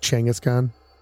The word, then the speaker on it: Genghis Khan: It's actually pronounced Genghis Khan. – Genghis? – Yeah, it's pronounced like Genghis or Genghis.